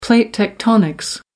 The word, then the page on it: plate tectonics: American English